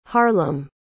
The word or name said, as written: harlem